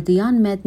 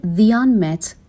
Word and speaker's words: the unmet: به تلفظ the unmet دقت بکنیم، چون you یک حرف صدادار هستش، با صدای آ شروع میشه این کلمه، حتما حرف تعریف the به صورت the خونده میشه.